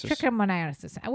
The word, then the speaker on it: trichomoniasis: Trichomoniasis.